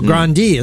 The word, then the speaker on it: Grande: In fact, Ariana Grande, or Grande as some people pronounce, did a cover of All I Want for Christmas Is You.